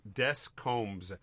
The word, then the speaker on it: Descombes: So it's if you pronounce it in English, it's Descombes. Descombes. Descombes.